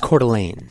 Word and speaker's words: Coeur d'Alene: It's pronounced Coeur d'Alene. – Coeur d'Alene? – Yes, it's French.